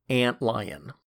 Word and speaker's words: Antlion: I'm going to go out on a limb and say that the late lamented lunar base of the current Doctor Doom series is pronounced Antlion, just like the bug plus the cat.